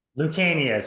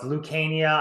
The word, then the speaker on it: Lucania: Lucania. So you sounded out phonetically the Lucania, which I get often, so don't feel bad about. Yeah. But it's a hard C. So it's Lucania.